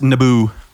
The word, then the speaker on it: Naboo: It's pronounced Naboo.